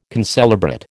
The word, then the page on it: concelebrate: American English